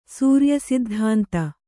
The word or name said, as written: ಸೂರ್ಯಸಿದ್ಧಾಂತ